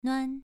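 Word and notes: nuan: tone 2

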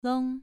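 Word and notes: long: tone 2

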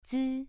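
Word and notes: zi: tone 2

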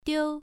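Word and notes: diu: tone 1

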